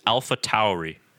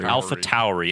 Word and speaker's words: AlphaTauri: But from what I understand, the correct pronunciation is AlphaTauri. AlphaTauri. And I believe it's because of the U in there.